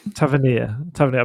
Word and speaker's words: Tavernier: I mean, he just completely lost, how do you pronounce it? Tavernier. – Tavernier. – I've been calling him Taverner the whole time. Tavernier.